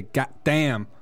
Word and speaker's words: Gat Damn: I'm gonna play Gat Damn off that album, G-A-T Damn. I thought I'd pronounce it properly, but yeah, this whole project's insane.